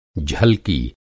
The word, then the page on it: झलकी: Hindi